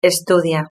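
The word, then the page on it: estudia: Spain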